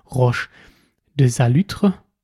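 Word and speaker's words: Roche de Solutré: Unter anderem am eindrucksvollsten, und das könnt ihr auch in diesem Video sehen, ist das einmal Roche de Salutre, so wird es ausgesprochen, und Roche de Vergisson.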